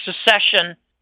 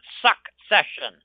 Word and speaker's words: secession: I have this discussion with respect to Texas rather frequently, and we believe Texas might make a move for secession. It's secession, not succession. It's SEC. For some people who misspell it and mispronounce it, it's secede from the union.